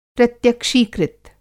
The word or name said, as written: प्रत्यक्षीकृत